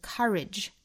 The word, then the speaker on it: courage: 那英音会读成一个 courage 倒三角啊那美音会倾向于把它读成 courage courage courageous courageous 连读 courage is not N-O-T